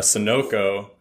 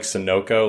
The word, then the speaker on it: Sunoco: And I'm like, oh shit, I've been saying it wrong all this time. Like gassed up like Sunoco. Like it's Sunoco, not Sunoco.